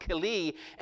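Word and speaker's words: keli (כלי): In the Hebrew, it's keli. It's K-L-I, or K-L-I-Y is how it's spelled. It's pronounced keli, and it means something prepared.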